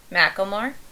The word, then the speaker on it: Macklemore: How do you say it? – Macklemore. – Macklemore? – Yeah. – Macklemore. Yeah. – Macklemore. Like, it's a first and last name of a French person. Macklemore.